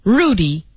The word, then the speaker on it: Rudy: We went there often enough to have our own special waiter, Rudy, a name he pronounced with a rolling R.